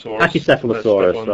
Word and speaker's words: Pachycephalosaurus: Pachycephalosaurus, right. Pronounce the CH hard, don't you? – Bit of a headbanger.